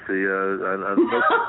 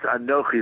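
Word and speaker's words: Anochi (אנכי): I heard you pronounce it earlier, much like a like an Italian delicacy. Anochi. But it's Anochi.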